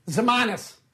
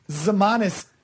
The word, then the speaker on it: Ximines: How would you pronounce a last name spelled Ximines? – Zimanis. – Zimanis. O'Shane Zimanis. – He's Greek. – O'Shane Zimanis. Ximines.